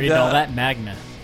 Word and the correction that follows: manga: It's pronounced manga.